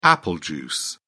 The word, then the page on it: apple juice: British English